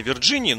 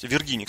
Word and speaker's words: Виргинии: какой какой-то во вшивой западной Вирджинии, но он есть, Виргинии, кстати, правильно говорить по-русски.